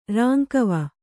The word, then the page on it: ರಾಂಕವ: kannada